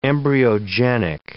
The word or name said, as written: embryogenic